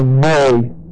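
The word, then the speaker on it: ne (νή): Okay, now this is just one particle, and the particle is the particle ne, N-E, long E.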